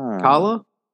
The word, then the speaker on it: Kala: But I don't know if she came up on a playlist or what, but MIA came up, and it was from the, I guess it's pronounced Kala, the record Kala.